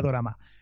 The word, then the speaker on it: dorama: Pueden ser comedias, pueden ser románticas, pueden ser de terror o thriller, y todas se, se denomina dorama, que es algo así como se le pronuncia en japonés drama, ¿vale?